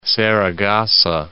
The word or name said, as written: Saragossa